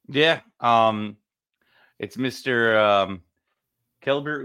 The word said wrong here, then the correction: Keibert: Ruiz. I said his name right. Kybert. Sorry.